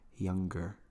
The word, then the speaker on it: younger: Y también hay como muchos mexicanos dicen como younger, pero la verdad es que tienes que pronunciar la palabra así como younger.